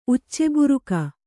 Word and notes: ಉಚ್ಚೆಬುರುಕ: Kannada

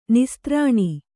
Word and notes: ನಿಸ್ತ್ರಾಣಿ: kannada

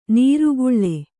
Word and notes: ನಿರ್ಗಾಳಿ: kannada

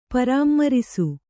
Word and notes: ಪರಾಮ್ಮರಿಸು: kannada; parāmmarisu

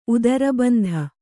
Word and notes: ಉದರಬಂಧ: udarabandha

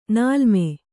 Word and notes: ನೀಲಿಮೆ: nīlime

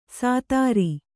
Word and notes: ಸಾತಾರಿ: kannada

